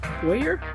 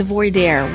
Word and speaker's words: voir dire: How do we pronounce that? – Voyeur? – No, the voir dire, where the sidebar.